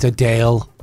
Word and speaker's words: Adele: Oh, it's Adele. – Adele. – She says Adele. – Adele. – With an accent.